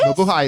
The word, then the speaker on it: Mabuhay: Greetings and Mahube. Did I say that right? Jay and crew, coming to you live from Manila and been a fan since... – It's Tagalog. – Oh, it is? – Mabuhay is it's Tagalog, yes.